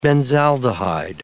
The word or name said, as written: benzaldehyde